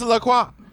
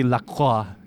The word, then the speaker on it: LaCroix: It's LaCroix. – LaCroix. I mean, it's like a karate chop, dude. LaCroix. – If it were legitimately French, it would be LaCroix.